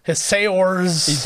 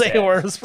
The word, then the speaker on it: Saoirse: Saoirse. – There you go. That's Saoirse. – Saoirse. – Saoirse.